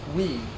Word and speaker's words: Wii: It is pronounced Wii, indicating its all-inclusive nature.